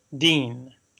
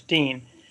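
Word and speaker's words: deen (دين): He said, well, religion in Arabic is deen, pronounced deen, almost like the man's name in English, deen.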